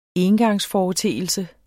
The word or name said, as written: engangsforeteelse